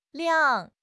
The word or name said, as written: 晾